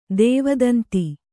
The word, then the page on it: ದೇವದಂತಿ: kannada